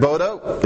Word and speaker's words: Bode: So Henrik Bode, his name is pronounced Bode, but everybody calls them Bode plots. So the guy's probably rolling over in his grave because everybody mispronounces his last name, but I will too.